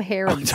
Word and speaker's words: harem: Considering it's an Arabic word, it probably makes sense, but it's actually pronounced harem. Haram. – Oh, harem. – Harem. – Haram. – Did you just say it twice? Originally, it comes from the Arabic word haram, which is like forbidden.